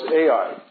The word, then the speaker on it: Ai: Then they went to the next city on their attack plan, which was Ai. Right, that's how you pronounce it. The name is spelled A-I, and it's pronounced A-I.